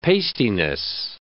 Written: pastiness

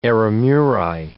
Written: eremuri

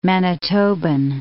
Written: Manitoban

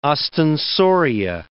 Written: ostensoria

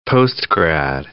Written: postgrad

